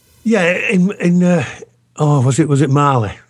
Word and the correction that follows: Bali: The Bali bomber. – Barley. Yeah, that's right. I have the pronunciation wrong.